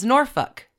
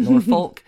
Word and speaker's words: Norfolk: I now know it is Norfolk, not Norfolk.